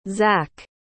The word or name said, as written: Zak